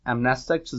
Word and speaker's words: amnestics: are any of their, like, amnestics, is that how it's pronounced? – Yep.